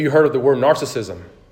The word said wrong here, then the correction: narcissism: Narcissism? Narcissism. I added an extra syllable there.